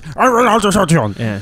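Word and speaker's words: Reynald de Châtillon: Reynald de Chatillon. – Yeah. – I think it's pronounced, Reynald de Chatillon, when he's in that little prison cell.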